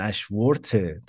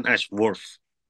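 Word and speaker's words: Ashworth: من نخو که توضیحش بدم دن اشورف من اینجوری تلفظش میکنه ولی اون آخر مثل برفدی مثلا یه همچه حالتی یه تی هیچی رو آخرش داره دنش برف ما این کلمه رو این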